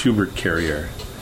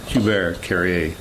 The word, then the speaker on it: Hubert Carrier: My actual real name was given name from my father, and it's Hubert Carrier. Hubert Carrier. – Oh, okay. – That's how you kind of pronounce it in French.